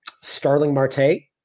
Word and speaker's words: Starling Marte: I believe his name is pronounced Starling Marte.